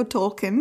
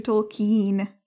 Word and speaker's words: Tolkien: ono celosvětově to říkají všichni špatně, protože pokud se nepletu, tak američani a angličani všichni to vyslovili jako Tolkien, mm-hmm. ale on to má být jakože Tolkien.